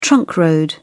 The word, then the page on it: trunk road: British English